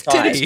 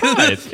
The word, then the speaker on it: Tithe: How is it pronounced? Do we have a... – It's Titty. – It's Tithe. – It's Tithe. – Tithe. Okay. I like Titty. Personally. – It's not Titty. – For those dear listening Lord. At home, T-I-T-H-E.